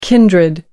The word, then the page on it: kindred: American English